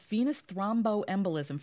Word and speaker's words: venous thromboembolism: So how many people, this venous thromboembolism, first of all, am I saying it right? – That's right.